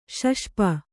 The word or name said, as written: ಶಷ್ಪ